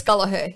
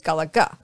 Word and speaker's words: Gallaher: It is G-A-L-L-A-H-E-R. Most people think there should be a second G, so it's Gallagher. But, it's not.